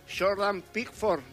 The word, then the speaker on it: Jordan Pickford: Jordan Pickford será el arquero.